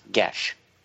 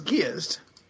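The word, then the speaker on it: geas: As a side note, the spell you're trying to pronounce from Dungeons & Dragons is pronounced Gesh. – I always thought it was Giezd. – No, it's Irish. It's Gesh. Okay.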